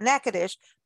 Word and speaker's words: Natchitoches: And so, of course, whenever we talk about Natchitoches, by the way, uh, it is spelled N-A-T-C-H-I-T-O-C-H-E-S, but pronounced Natchitoches.